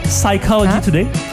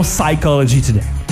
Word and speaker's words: Psychology Today: Psychology Today. Ce qui m'occasionne aussi le fait de rappeler qu'on prononce pas le P dans Psychology Today.